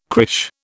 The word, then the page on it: crèche: British English